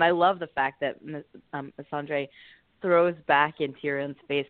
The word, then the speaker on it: Missandei: I found it very interesting the conversations that he has between Grey Worm and how do I say her name? Missandei? – I think it's Miss and I. – Missandei, yeah.